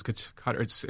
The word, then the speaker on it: Qatar: Qatar is the correct pronunciation.